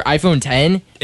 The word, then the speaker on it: iPhone X: So Apple just released iPhone X or iPhone 10. – It's pronounced 10. – It's like Roman numeral 10.